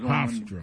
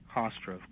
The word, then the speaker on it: Hofstra: Was it Hofstra University? I'm pronouncing it wrong. Was Hofstra. – On Hofstra, correct.